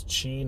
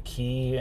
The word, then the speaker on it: qi: like the like QI. It, it's chi, not key.